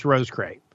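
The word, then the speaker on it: Roscrea: That's R-O-S-C-R-E-A, pronounced Rose Cray.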